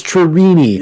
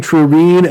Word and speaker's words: Trini: But it's Trini. – Yeah, Like, they didn't seem to know, because I think people were saying Trine and Trini. Let's call her Trini. – Trini. – It's T-R-I-R-E-N-E.